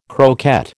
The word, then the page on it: croquette: American English